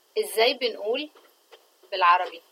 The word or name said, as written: izzay min2ul